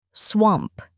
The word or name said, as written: swamp